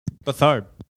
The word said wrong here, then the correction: Beathard: You keep mispronouncing that. It's Beathard.